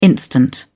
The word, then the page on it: instant: British English